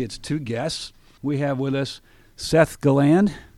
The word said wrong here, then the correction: Gilland: Did I pronounce that right, Seth? Seth Gilland. Why do I want to say Galland? – Are you not French?